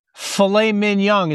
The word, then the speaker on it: filet mignon: Fred, it's filet mignon. It's French. – It's French. So no mignon, you said? – The G is silent, Fred. It's French.